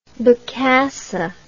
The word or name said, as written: Bokassa I